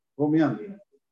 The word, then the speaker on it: rumiante: pero por dentro él no es ruminante, se dice así en español, ¿no? Rumiante.